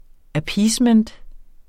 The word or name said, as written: appeasement